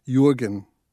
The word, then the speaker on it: Jurgen: By the way, the book's title and the main character's name, spelled J-U-R-G-E-N, ought technically, I suppose, to be pronounced Jurgen. But I think I am in agreement with the general American convention in pronouncing it Jurgen.